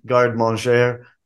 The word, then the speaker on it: garde-manger: And I had to look up the term garde-manger.